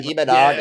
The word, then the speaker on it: Imanaga: So word out of the hubbub, scuttlebutt, I don't know, right, is that Imanaga, if I'm saying it right. – Imanaga.